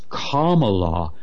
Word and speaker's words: Kamala: Also, I've said before how Kamala Harris, turns out I was mispronouncing her name, she now insists that it's Kamala Harris. And what does that rhyme with, by the way? Kamala, Obama.